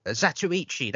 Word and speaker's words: Zatoichi: Zoytoch. I do know how to pronounce it, but I've forgotten. I didn't know how to pronounce it. Zatoichi, that's it. Zatoichi, I think is how you pronounce it.